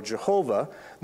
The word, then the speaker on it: Yahweh: The Lord is mentioned twice there through the tetragrammaton, as it's called, the four letters Yod, He, Vav, He, which most scholars now think is Yahweh's terms of how it was originally pronounced. Some say it should be translated Jehovah, though that's kind of an older opinion.